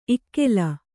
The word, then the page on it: ಇಕ್ಕಲು: ikkalu